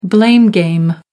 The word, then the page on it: blame game: American English